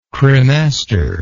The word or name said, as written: cremaster